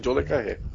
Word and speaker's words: John Le Carré: Ele colocou que uma boa recomendação que ele pode fazer é o livro do escritor John Le Carré. Não sei como pronuncia isso. – É assim mesmo. – Carré? – Uhum, exatamente, John Le Carré.